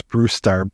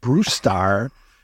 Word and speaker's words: Brusstar: I love that the guy's name is Brewstar. B-R-U-S-S-T-A-R, I believe it's spelled. – Yeah. – Brewstar.